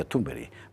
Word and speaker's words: Thunberg: Quiero decir que personalidades psicopáticas como la de Greta Thunberg, Carlos Esteban nos enseñó que se pronuncia Thunberg.